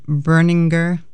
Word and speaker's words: Berninger: Matt Berninger and Roseanne Cash after that, fairly new music, Who Loves the Sun, followed by Matt Berninger, that's how you say it, on his own doing the Inland Ocean.